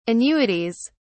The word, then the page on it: annuities: British English